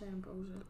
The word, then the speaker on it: Ambrosius: Did I say her name right? Ambrosius? – Mm-hmm. Marsha Ambrosius.